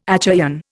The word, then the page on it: Achaean: Indian